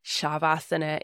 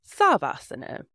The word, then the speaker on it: Shavasana: For example, like I said much earlier on, Shavasana instead of Savasana.